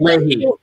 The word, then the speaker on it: Leahy: Leahy. – Leahy, who will preside over Trump impeachment.